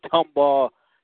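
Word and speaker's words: Tomball: Temple of Refuge Ministries, as the Dr. Mike Prince is giving me the thumbs up for pronouncing Tumball, Texas correctly, as he is the mastermind behind this Open Mic Broadcast Network.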